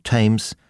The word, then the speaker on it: Thames: Außerdem muss man die Ausnahmen kennen. Als denglische Patienten brauchen wir uns nämlich gar nicht mit der Aussprache von TH abzumühen, wenn es ganz einfach wie ein T gesprochen wird, in Namen wie Theresa, Thomas, Thompson, Thames, Thai, Lesotho oder Thyme, dem Gewürz Thymian.